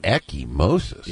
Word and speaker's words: ecchymosis: Ecchymosis. E-C-C-H-Y-M-O-S-I-S.